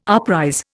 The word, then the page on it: uprise: Indian